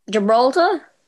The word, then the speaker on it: Gibraltar: How do you say that? Hold on a second. That's not how you say that. – Gibraltar? – Yeah, that's correct.